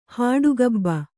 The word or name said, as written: ಹಾಡುಗಬ್ಬ